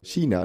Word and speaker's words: Kina: Oder auch China, das Land. Das wird auf Schwedisch mhm. K-I-N-A geschrieben.